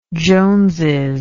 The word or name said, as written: joneses